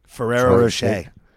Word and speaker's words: Ferrero Rocher: Her mom put Pringles and Rocher. How do you say the chocolate balls? – Ferrero Rocher.